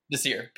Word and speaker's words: Desir: I mean, I know signing Pierre Desir, is that how you say it? – Desir, Pierre Desir.